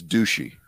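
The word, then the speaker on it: Doocy: That was the actual question that Peter Ducey asked her this week. – You're saying it wrong. It's Ducey.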